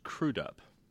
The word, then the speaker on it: Crudup: Also, it's pronounced crudup, according to the man himself.